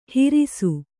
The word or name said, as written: ಹಿರಿಸು